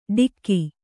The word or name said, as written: ಢಿಕ್ಕಿ